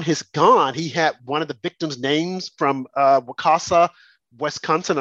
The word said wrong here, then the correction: Waukesha: And I'm probably mispronouncing that name. Wakasha, Wisconsin. – Waukesha.